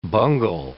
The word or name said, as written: bungle